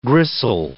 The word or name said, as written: gristle